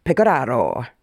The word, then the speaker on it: Pecoraro: She got it Pecoraro? – Bang on. – Yeah. Pecoraro. – It's supposed to be Pecoraro in Italian.